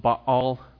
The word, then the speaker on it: Baal: It's actually pronounced Baal. That's how you pronounce it. And for whatever reason, most of us, even though we know that, we still just say Baal.